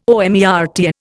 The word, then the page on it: omertà: Indian